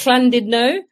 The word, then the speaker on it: Llandudno: Was that Llandudno? – Yeah, Llandudno. They don't pronounce it like that. – Llandudno. I can never pronounce it right. It's Llandudno.